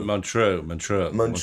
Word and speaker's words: Montreux: Montreux. I say it with a... – Yes, you say it properly.